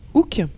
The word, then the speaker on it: Hook: Euh, donc euh, du coup oui, on, vous parlait donc hein, on vous remettait un peu l'histoire de du film Hook, Hook, pardon. Euh, le H se prononce en anglais.